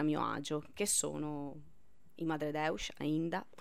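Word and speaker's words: Madredeus: Io non la pronuncio portoghese, come si dice bene. – Ma credo Madre Deus. – Ecco, perfetto, tu hai pronunciato perfettamente, dimmi.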